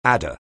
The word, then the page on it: adder: anglais